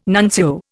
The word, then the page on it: nuncio: Indian